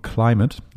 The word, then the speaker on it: Klymit: Und ähm, habe ähm, im Internet eine Matte gesehen, die habe ich mir immer bestellt von der Firma Klümet, beziehungsweise so wird es geschrieben, wird wahrscheinlich ausgesprochen Climate, also wie, wie, wie ähm, Klima, aber geschrieben aber K. Mathe, M -A -T. KLY, ne? Ganz witzig. Mhm. K-L-Y-M-I-T.